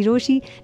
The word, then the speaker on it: Rochie: मेरा नाम है मिर्ची रोशी, नाम के स्पेलिंग है ROCHIE, and I am the girl who believes in love.